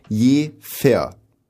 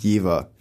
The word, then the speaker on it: Jever: Und die Stadt wird phonetisch ausgesprochen. Je fair. – Ja. – Nicht Jever.